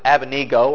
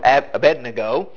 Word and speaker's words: Abednego: "There are certain Jews whom thou hast set over the affairs of the province of Babylon, Shadrach, Meshach, and some pronounce it Abednego or Abednego. These men, O king, have not regarded thee.